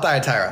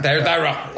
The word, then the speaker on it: Thyatira: I call it Thyatira. – Thyatira, okay. – And you can put the emphasis on any syllable you want, sir.